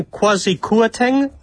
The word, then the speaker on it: Kwasi Kwarteng: Primero, saber cómo pronunciarlo y cómo decirlo, ¿no? Por su origen y por su apellido bastante lleno de consonantes, ¿no? – Sí, sí, aquí le llaman Kwasi Kwarteng, de 47 años.